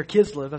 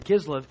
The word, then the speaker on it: Kislev: Now, the month there, Kislev, excuse me, I pronounced it wrong there, Kislev is November and December in our time.